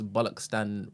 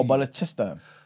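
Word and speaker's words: Balochistan: And also, I think we pronounce Bollockstan wrong. Apparently, it's a CHA song, not a K. – Oh, at the what Bollockstan? Oh, Bollockchistan.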